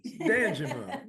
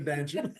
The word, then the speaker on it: Danjuma: There's Charles Thornton and Dejuma Gaskins. Did I pronounce that right, sir? – Hey, sir, nice try. It's Danjuma Gaskins. – Danjuma. Well, okay, Danjuma. I like Dejuma. Let's go with Danjuma.